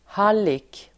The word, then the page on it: hallick: [h'al:ik]